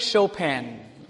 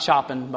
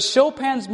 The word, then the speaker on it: Chopin: Frederick Chopin. Anybody ever heard of Chopin? It's not Chopin, by the way. I have to look at that and remember, don't say it like it looks.